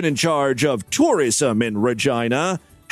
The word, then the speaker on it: Regina: The answer is Regina. And yes, it's pronounced Regina.